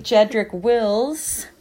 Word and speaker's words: Jedrick Wills: The Giants are going to take Jedrick Wills. I've been told that's how you say his name, out of Alabama.